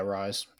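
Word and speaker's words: Rhys: How do you say his name? John. Is it John Rise? – Yeah, Rise. Yeah, Rise Plumlee.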